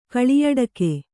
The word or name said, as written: ಕಳಿಯಡಕೆ